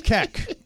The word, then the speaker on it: Keck: Keck. – K-E-C-K? – Yes.